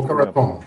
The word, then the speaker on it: Boca Raton: Eh, eh, vi ska prata om eh, de här dagarna nere i Florida, Boca Raton, eller Raton. Det är också uttal. – Boca Raton.